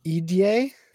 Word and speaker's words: Edie: So I picked, and I apparently have been saying her name wrong forever, but it's Edie. – Oh, Edie. – Yeah. – I always said Edie. – I've always said Edie too, but I specifically was looking her and this other person up last night, and there was a pronunciation guide, and I was like, wow, I'm so wrong.